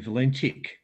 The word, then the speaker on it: Valentich: But look, the Americans call him Valentich. It's not Tick. It's Titch.